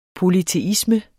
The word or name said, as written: polyteisme